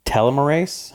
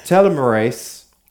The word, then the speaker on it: telomerase: telomerase. – Yeah, that's it. Yeah, how do you say that? – Telomerase.